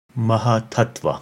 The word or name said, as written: Mahattattva